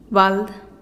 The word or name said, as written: bald